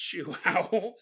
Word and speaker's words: João: It's spelled J-O-A-O. Google told me it was pronounced João, so that's what I was going with.